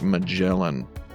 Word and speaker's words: Magellan: Uncharted Waters, because it's a game where you sail the ocean like Magellan, which rhymes with melon.